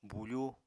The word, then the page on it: bufú: Apatani